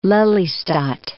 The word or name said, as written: Lelystad